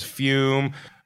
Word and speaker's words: Fum: Of course, that is Fume. Pronounced F-U-M-E, but spelled F-U-M.